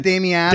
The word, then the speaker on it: Damiano: Is it Damiano? – Damiano, yes. But, you know, I lived in Italy for some years and learned to say Gerardo Rocco Damiano.